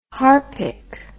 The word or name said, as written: harpic